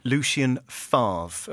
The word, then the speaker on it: Lucien Favre: Producer Ben is so scared about my inability to pronounce foreign names that he's written this phonetically. Lucien Favre.